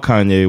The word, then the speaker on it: Kanye: and then like hearing about Kanye West, and I used to, I thought his name was Kane West.